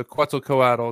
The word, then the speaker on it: Quetzalcoatl: So that would be Quetzalcoatl, or Quetzalcoatl, as it's sometimes pronounced, you know. – Yeah. – It's different people, you'll hear different pronunciations. So Quetzalcoatl is how I pronounce it.